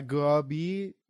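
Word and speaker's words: Grubbly: گرابی یعنی این الش تلفظ نمیشه شبیه واژه گرابی تلفظ میشه که به معنی کثیفه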